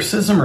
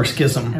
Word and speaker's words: schism: It's either schism or schism. – Okay. Well, I grew up saying schism like a both ways.